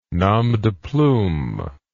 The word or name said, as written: nom de plume